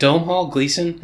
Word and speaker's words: Domhnall Gleeson: So, is it Domhnall Gleeson? Is that how you say his name? – Yep.